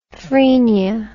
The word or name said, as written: phrenia